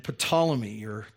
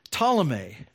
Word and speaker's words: Ptolemy: And the two family names that you're going to see are the Seleucids and Ptolemy. The silent P always gets me.